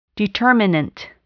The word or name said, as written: determinant